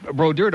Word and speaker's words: Brodeur: I mean, Brodeur don't rhyme with loser.